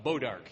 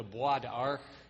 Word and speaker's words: bois d'arc: You might know it as a hedge apple, or when you mispronounce French, Beau d'Arc here. The French called it the bois d'arc, which is the wood of the bow, and most of the Southern Americans didn't speak much French, and it came out Beau d'Arc when they heard it pronounced by a Frenchman.